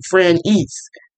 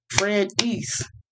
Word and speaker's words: Franese: And people not willing to try to say a 7-letter name, Franese. It's 2 syllables, Franese. If you want to do 3 syllables, for rent and niece, that was like 4.